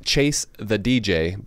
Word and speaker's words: Che se DJ: You're supposed to say it as Chase the DJ, but so this is an Italian style pilsner, and it's Che se DJ.